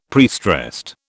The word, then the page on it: prestressed: British English